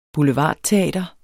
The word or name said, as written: boulevardteater